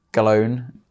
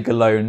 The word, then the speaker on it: Gallone: Gallone. I'm going to say Gallone, not Gallone, which is the actual pronunciation, because that would confuse people. Mm-hmm. So just Luca Gallone on Facebook, Instagram.